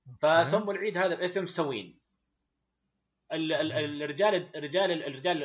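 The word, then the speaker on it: Samhain: اسمه سوين بالانجليزي طبعا ينطق سوين بس كتابته مختلفه لانه اللغه الكلتيه أوكي.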